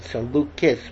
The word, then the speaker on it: Seleucus: Now, you pronounce it Seleucus, but when it's a dynasty, it's Seleucid for some reason. The C becomes a soft C.